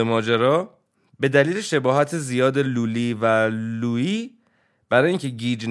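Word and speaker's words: Lully: البته تلفظ انگلیسیش لالیه، ولی ما تلفظ فرانسویش رو میگیم لولی.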